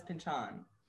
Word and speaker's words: Pynchon: Well, I think we should take his word for it, because he pronounces his own name when he has that cameo on The Simpsons. I believe he says Pynchon.